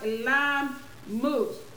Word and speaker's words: Elon Musk: Is Elon Musk? Okay, thank you, Robin Jones. Thank you. She said it's pronounced Elon Musk.